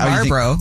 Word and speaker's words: Marlboro: M-A-R-L-B-O-R-O. – How do you say it? – Marlboro. And then the L goes away. Because I basically was going to say at the beginning of this, I don't have this problem, but now I'm a little confused. – No, the L's still there. It's Marlboro.